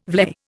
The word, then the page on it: vlei: Indian